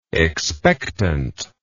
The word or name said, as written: expectantly